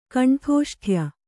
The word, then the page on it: ಕಂಠೋಷ್ಠ್ಯ: kaṇṭhōṣṭhya